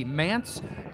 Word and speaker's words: Mantz: Now, his name is spelled M-A-N-T-Z, Mance Pain Chamber.